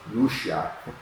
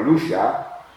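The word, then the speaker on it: Lúcia: We call them Lucia, Francisco, and Jacinta, but when I was there, I learned it's pronounced Lucia. The Portuguese call her Lucia, Francisco, and Jacinta.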